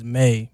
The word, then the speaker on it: M2YE: So my name is Edwin, and my artist name is May, but it's spelled M2YE.